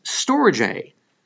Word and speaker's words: storge: The first one, though, is pronounced storge. It's spelled S-T-O-R-G-E.